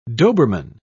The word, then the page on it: doberman: British English